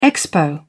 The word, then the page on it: expo: British English